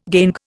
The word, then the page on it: gangue: Indian